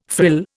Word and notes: frill: Indian

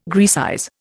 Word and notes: Graecize: Indian

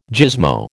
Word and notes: gizmo: American English

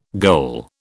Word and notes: goal: British English

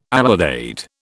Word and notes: alidade: British English

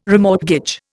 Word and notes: remortgage: Indian

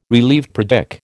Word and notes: relieved: American English

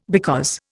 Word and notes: because: Indian

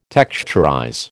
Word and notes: texturize: American English

